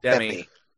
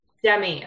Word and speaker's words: Demme: So this movie was directed by Jonathan Demme. Is that how I pronounce it? – Demme. – Demme. Okay. I was worried I got that wrong.